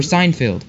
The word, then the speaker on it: Seinfeld: Oh, it's from Steinfeld. Or Seinfeld.